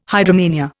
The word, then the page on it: hydromania: Indian